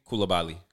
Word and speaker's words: Coulibaly: I was like, is it Kulubi? – Coulibaly. – Coulibaly.